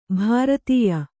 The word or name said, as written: ಭಾರತೇಯ